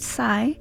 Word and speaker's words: SY3: This is her latest track, and then after that was another LA-based artist, spelled S-Y-3 and pronounced Psy, with their track Tell Me.